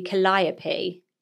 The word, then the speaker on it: Calliope: That name and we pronounced it wrong. Calliope? – Oh, yes. – Oh, we had loads of people coming in saying that was on Grey's Anatomy. – And it should be Calliope.